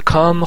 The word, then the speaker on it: calm: calm ال تلفظ نمیشه، calm